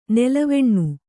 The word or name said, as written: ನೆಲವೆಣ್ಣು